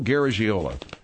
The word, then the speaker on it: Garagiola: I knew you'd butcher it. Joe Garagiola.